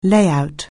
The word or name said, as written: layout